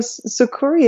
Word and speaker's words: Sucuri: Yeah, so Sucuri is actually a Brazilian Portuguese word. We don't mind if people say Sucuri, but we do have a whole history about where the name came from on our website, on our about page.